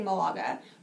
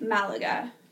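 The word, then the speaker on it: Málaga: But we kept saying Malaga. – Yeah. – But it's pronounced Malaga. – That is the correct way to say it.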